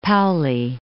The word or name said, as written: Paoli